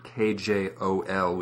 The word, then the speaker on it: kjol: and this would appear in Swedish spelling KJ, as in the word KJOL, which means skirt, which would be pronounced...